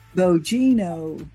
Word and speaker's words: Bogino: Bogino.